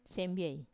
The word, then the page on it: sembie: Wamesa